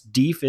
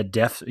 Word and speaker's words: Deaf: And yes, Deef is a correct pronunciation of the Deaf, you know, we may say it Deaf Smith today, but it was actually Deef Smith.